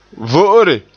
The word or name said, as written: vo'ore